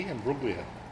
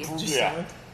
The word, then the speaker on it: Imbruglia: Do you not say the G? Imbruglia. – No, you don't say the G. – Imbruglia. – Why is the G silent? – I'm not Australian or Natalie Imbruglia, so I'm not going to answer that.